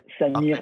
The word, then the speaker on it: Samir: Euh, je prononce bien ton nom, je t'ai même pas demandé avant. Ouais, ah, c'est bien ça, Samir ah. Labidi.